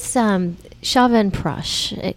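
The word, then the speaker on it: Chyawanprash: What is Shavan Prash? I'm hoping I'm saying that right. – You are. Very good. Good job.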